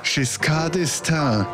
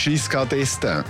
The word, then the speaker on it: Giscard d'Estaing: Die Deutschen wiederum, die sagen, Giscard d'Estaing. Das ist Herr Giscard d'Estaing. Und wir sagen, das ist der Giscard d'Estaing. Und wenn die Deutschen etwas bei uns auf der ersten Silbe betonen, dann betonen wir es hinten.